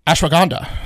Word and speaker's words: ashwagandha: Just say it faster. – Ashwagandha. – Yeah. – Say it like you nailed it.